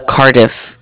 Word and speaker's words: Cardiff: Of Cardiff. It's spelled C-A-R-D-I-F-F, like different.